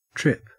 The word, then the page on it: tip: Australian English